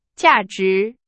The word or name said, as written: 价值